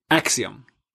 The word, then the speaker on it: axiom: 15 innan jag börjar sjunga där, nu får ni hjälpa mig hur man uttalar nu igen, Axiom. – Ja, axiom heter det på svenska, jag vet inte hur det säger okay.